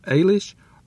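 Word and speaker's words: Eilís: Set in the 1950s, this story follows young E-I-L-I-S, pronounced Eilish or Eilish from the Irish language, as she moves to America on her own from her home country of Ireland.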